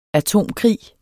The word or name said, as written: atomkrig